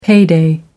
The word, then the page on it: payday: American English